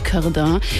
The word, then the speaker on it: Corday: Meine Nummer 1, Memories von Charlotte Corday oder französisch ausgesprochen Charlotte Corday.